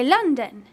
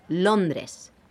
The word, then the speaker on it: Londres: Soy de London. – No, not London. It's Londres.